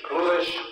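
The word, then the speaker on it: Clewis: My name is Cluis Demery, C-L-E-W-I-S-D-E-M-O-R-Y.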